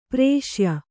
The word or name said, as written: ಪೌರುಷೇಯ